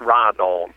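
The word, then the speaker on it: Riton: It's R-I-T-O-N. – Okay. – And it's pronounced Ride-On.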